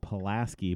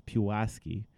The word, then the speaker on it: Pulaski: Most people would pronounce it Pulaski, but it is Pulaski.